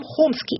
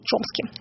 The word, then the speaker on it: Хомски: не все согласны с тем, как произносится его фамилия. Это Ноам Хомски или Чомски.